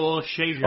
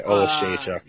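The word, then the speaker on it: Oleksiejczuk: whose name I butchered, but he doesn't deserve me to say it correctly because he's not as handsome as our boy Modestus, and he lost that fight. – Yeah, I agree with you. Michael Olszewczyk. – Oh, that's right. Olszewczyk. – Yeah, that's right. I got the Polish pronunciation here.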